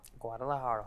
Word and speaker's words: Guadalajara: Guadalajara.